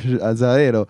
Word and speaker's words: Azzarello: Azzarello.